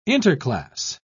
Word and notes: interclass: British English

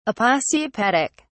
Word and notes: aposiopetic: British English

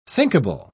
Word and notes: thinkable: British English

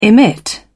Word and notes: emit: British English

